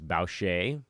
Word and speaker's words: Boucher: And then Chris uh, Boucher, I think it's Boucher. I don't think you pronounce the R, but I could be getting that wrong.